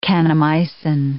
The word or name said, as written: kanamycin